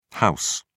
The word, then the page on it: ho: British English